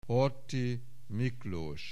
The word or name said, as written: Horthy, Miklós